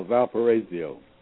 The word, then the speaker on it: Valparaiso: Michigan State will play, I have no idea how to pronounce that, Valparaiso? – Valparaiso.